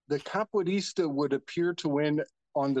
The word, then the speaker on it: capoeirista: Capoista? – You got a capoeirista.